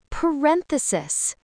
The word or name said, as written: parenthesis